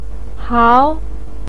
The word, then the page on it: hao2: pinyin